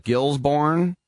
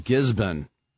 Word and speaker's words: Gisborne: It looks like Gilsbourne, but we know that with Melbourne, you never pronounce the R. It's Melbourne. – Mm-hmm. – So he's from Gisborne.